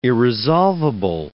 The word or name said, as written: irresolvable